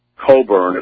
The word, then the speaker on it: Cockburn: A major publisher, HarperCollins, and Andrew and Leslie Coburn, it's spelled C-O-C-K-B-U-R-N, and, you know, it was pulled.